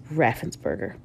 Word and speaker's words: Raffensperger: I hope I said that right. Raffensperger. Yes, Raffensperger.